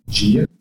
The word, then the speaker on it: dia: Gia. – Gia. So D-I-A says Gia. So when you learn English, you had to learn how to pronounce letters differently.